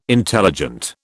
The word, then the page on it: intelligent: British English